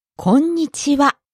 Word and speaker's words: こんにちは: Now, this word is well known even among people who don't speak Japanese, but those people may not know the correct pronunciation. Can we hear it again? Listen carefully. – こんにちは。 – こんにちは。You hold the N sound for a little bit, don't you? – That's right.